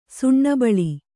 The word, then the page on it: ಸುಣ್ಣಬಳಿ: suṇṇa baḷi